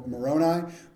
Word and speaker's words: Moroni: An angel named Moroni Okay, he said it pronounced Mor- Moroni, but it's moron with an I at the end.